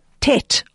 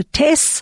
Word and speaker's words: Tet: We are going to be looking into the letter Tet, or Ashkenazi pronunciation, the letter Tes.